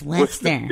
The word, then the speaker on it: Worcester: And it's it's Worcester. – Like Worcestershire sauce.